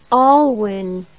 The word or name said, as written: alwyn